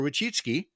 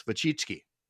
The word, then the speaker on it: Wojcicki: And what people don't know, and I'll put it up on screen for people watching on Facebook, her last name is spelled W-O-J-C-I-C-K-I. And that's Wachitsky, I think. At least a couple of times I've heard her pronounce it, you know.